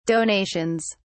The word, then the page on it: donations: British English